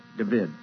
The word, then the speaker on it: David: David is the way it's pronounced down there.